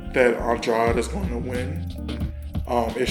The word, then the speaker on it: Andrade: Is it Andre or is that how you pronounce his last name? – What did I just say? – Andre. – I think it's Andrade. – That's what I was asking because I'm like, I thought it was Andrade. – Always, yeah. Because then you'll go in some other areas where they have other Andrades and they pronounce it Andre.